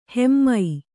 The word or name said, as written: ಹೈಮ